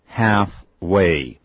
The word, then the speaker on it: halfway: H-A-L-F.W-A-Y, 一个字 H-A-L-F-W-A-Y, 那个 L 不发音的。 – 不要念成呢 ,half, 念 half, halfway, 很有意思哈、哦。好啦，就是说我们大家彼此在道路的这个一半，你走一半我走一半，彼此来个妥协好了，也就等于 I'll compromise with you。刚才这个字 compromise,好棒的一个字，妥协。 – C-O-M-P-R-O-M-I-S-E. Again, C-O-M-P-R-O-M-I-S-E.